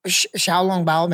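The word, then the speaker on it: xiaolongbao: Xiaolong Bao, maybe I'm saying that wrong.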